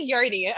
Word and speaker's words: yoni: I'm like, what's a yoni?